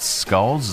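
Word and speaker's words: sculls: Number four, Bobby Pearce from Australia won the gold medal in men's single skull. Is that skulls? Is that how you pronounce it? – Skulls. – Skulls. Okay.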